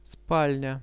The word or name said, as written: spalnia